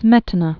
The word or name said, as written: Smetana, Bedrich